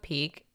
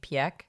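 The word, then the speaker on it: Pieck: Speaking of Peek, I think this whole time we've been pronouncing her name Piek, because that's how it's spelled. – Who the Piek cares? – So we're going to switch it up and follow everyone else and just say Peek, because they also say peak in the sub, so that's fine.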